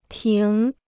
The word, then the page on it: 停: tíng